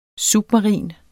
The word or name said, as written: SUBMARINT